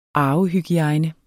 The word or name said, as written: arvehygiejne